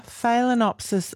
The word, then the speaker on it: Phalaenopsis: Are Phalaenopsis orchids okay in the bathroom? Did I pronounce that correctly? – You did, certainly did.